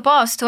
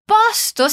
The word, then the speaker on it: posto: Posto significa lugar ou local. Perceba che tem uma diferença entre a pronúncia do primeiro O e do segundo.